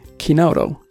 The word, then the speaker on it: chinotto: What I would like to talk about is the drink chinotto, pronounced in Italian, chinotto. You would say, I suppose, with English accent.